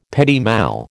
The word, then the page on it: petit mal: American English